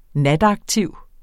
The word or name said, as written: nataktiv